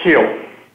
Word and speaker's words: Kiel: Michael, is it pronounced Kiel or Kyle? – It's pronounced Kiel.